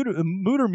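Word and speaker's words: Mütter: It's spelled Mutter, M-U-T-T-E-R, but the U has those two dots on top, like Motley Crue. So that's pronounced ooh, oh, yeah. Right? Like Mutter? – Or Thor's hammer.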